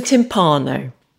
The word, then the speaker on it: timpano: Okay, a timpano or timpano or timbal or no. Timpano is how we pronounce it. You're pronouncing it correctly, but that's how my family pronounce it.